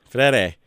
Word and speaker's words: Freire: Freire.